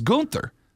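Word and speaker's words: Günther: But if you go to Austria, where he's from, it's pronounced Gunther. It's not an accent. That's how the name's pronounced.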